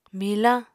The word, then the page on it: milañ: Apatani